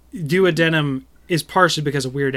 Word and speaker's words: duodenum: I just call it duodenum. – That's how you say it, duodenum. – Oh, cool. – Oh, well, there you go. That's how that word is said.